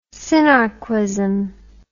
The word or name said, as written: sinarquism